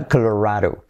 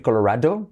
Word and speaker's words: Colorado: a Colorado. Y la D como R suave, semiclave, Colorado. Puedes decir Colorado, pero nadie lo dice así, es Colorado. Incluso lo dicen como una sílaba, Colorado, Colorado, Colorado.